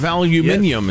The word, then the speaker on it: aluminium: The Canadians, our biggest supplier of aluminium, as I prefer to pronounce it, they're getting all angry.